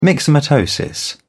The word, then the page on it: myxomatosis: British English